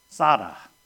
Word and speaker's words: tsadde: T-S-A-D-D-E. And you just barely pronounce that T, and you get to the S, and it's Sada. Sada. Barely get on the T, and then you get the S. Sada.